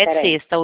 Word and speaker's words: Credicesta: Credicesta, o D é mudo.